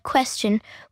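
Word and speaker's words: Qwstion: And that is spelt like the word question, with W replacing the letters U and E.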